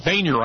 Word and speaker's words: Boehner: I can't figure out how the spelling out of this, how somebody gets Boehner out of this, but the guy's last name is spelled B-O-E-H-N-E-R. So it sure didn't look like Boehner to me,